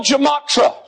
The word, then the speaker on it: gematria: Using a method called the gematria.